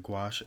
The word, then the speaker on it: gouache: And gouache. Am I saying that right? – Yeah, you're saying it right. Gouache, yeah.